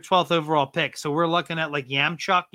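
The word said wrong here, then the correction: Yakemchuk: Is that how it's pronounced? – Yakumchuk. – Oh, Yakumchuk.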